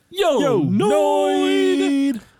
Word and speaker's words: Yo, Noid: Thank you, because it sounded like you said Yo, Noid, and I'm like, what's that? – No, it's pronounced Yo, Noid!